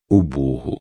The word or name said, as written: burro